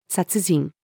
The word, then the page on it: 殺人: female